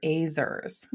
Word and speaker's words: ezer: I was reading it Ezer the whole time I was reading your book. But Azer, it's empowering.